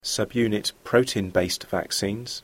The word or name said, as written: subunit protein based vaccines